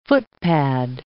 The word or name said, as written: footpad